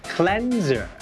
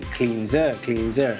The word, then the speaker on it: cleanser: ต้องออกเสียงว่า cleanser ไม่ใช่ cleanser